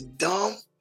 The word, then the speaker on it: dumb: The B on the end of dumb. That B on the end of dumb, you don't hear. That dumbass. – Dumb. Think about that, y'all. Dumb is spelled D-U-M.